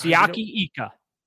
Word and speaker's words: Siaki Ika: At 98, Sitake Aika, right? That's how you say it. – Siaki. Siaki. His first name is Siaki. – What did I say? Ika. – It's Siaki Ika.